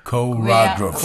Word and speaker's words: choreographer: Is it the right pronounce? – Choreographer. – Choreographer. Yeah.